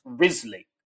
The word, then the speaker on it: Wriothesley: It's spelt WRIOTHESLEY or something like that, but it's pronounced Risley.